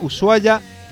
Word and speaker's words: Ushuaia: Y seguimos con el cine, el Festival Internacional de Cine de Montaña Ushuaia, así es, y así se pronuncia, según nos dijo una de las organizadoras programas atrás,